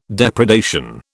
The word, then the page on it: depredation: British English